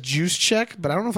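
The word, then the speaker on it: Juszczyk: Is it Juice Check? – Juice Check. – Juice Check? – Yeah, you said Juice Checker.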